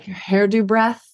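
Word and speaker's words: Herðubreið: And it's called like Hairdo Breath is sort of how you would pronounce it in English.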